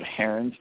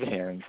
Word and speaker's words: Heeren: My last name is spelled Heron, spelled H-E-E-R-E-N. First name David. And I've been using an initial S, S as in Sam, for my Christian-related books. I use Dave Heron for my sports books and David S.